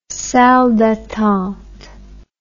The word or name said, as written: salle d'attente